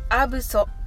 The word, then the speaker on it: absolutely: はどちらも舌先を上の前歯の付け根につけて発音してくださいそしてカタカナではアブソと表現されていますが B の発音の時にはブとは言わずただ口を閉じるだけですその後にソを言い